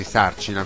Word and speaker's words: Sarcina: Adesso si è sciolto il gruppo, è uscito il leader, Francesco Sarcina, e lui ci tiene tantissimo affinché il suo cognome sia detto in modo corretto, quindi Sarcina con l'accento sulla A, mi raccomando.